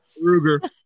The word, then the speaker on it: Maroger: And you know, that's one of the advantages of using that as the French Marigé, but most Americans call it Maruger. – Maruger. I've never heard anybody call it that. – Maruger. By the way, it's spelled Maroger. Maruger.